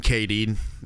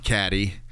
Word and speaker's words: Cady: So it follows a girl named Katie, not Caddy. Her name is spelled C-A-D-Y. – But come on, man. – C-A-D-Y.